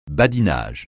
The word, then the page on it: badinage: francais